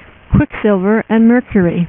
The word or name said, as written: Quicksilver